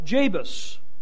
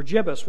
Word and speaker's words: Jebus: Some years later, it was called Jabus, or Jebus, whichever way you pronounce it, in Joshua's time.